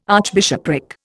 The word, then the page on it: archbishopric: Indian